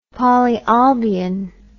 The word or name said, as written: polyolbion